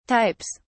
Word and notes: types: British English

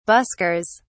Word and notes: buskers: British English

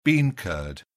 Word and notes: bean curd: British English

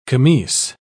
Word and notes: camise: British English

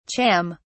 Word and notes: cham: British English

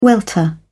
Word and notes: welter: British English

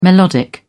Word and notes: melodic: British English

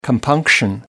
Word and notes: compunction: American English

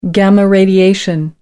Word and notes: gamma radiation: American English

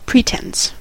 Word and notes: pretense: American English

